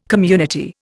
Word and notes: community: Indian

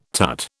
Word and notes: tut: British English

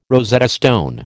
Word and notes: Rosetta stone: American English

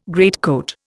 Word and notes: greatcoat: Indian

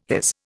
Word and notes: his: Indian

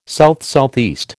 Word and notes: SSE: American English

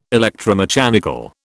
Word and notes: electromechanical: British English

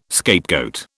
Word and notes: scapegoat: British English

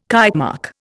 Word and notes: Kitemark: Indian

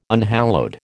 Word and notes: unhallowed: American English

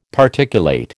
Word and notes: Particulate: American English

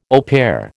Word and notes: au pair: American English

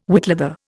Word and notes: whitleather: Indian

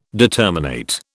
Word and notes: determinate: British English

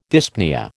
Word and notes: dyspnoea: American English

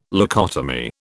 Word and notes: leucotomy: British English

